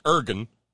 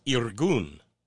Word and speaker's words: Irgun: I-R-G-U-N. – Which is not pronounced Ergun. It's Irgun.